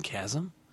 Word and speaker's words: chasm: Like, remember I was pronouncing chasm. – Yeah. – And then we were gaming one time. I said, there's a big chasm. And everyone went, you mean a chasm? – Yeah. – I was like, you're kidding me. – Yeah. – It's pronounced that way.